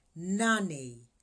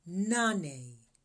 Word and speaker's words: nane: 8 is pronounced nane, nane.